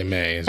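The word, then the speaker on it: Mei: How you pronounce it? May. – May. Just say May.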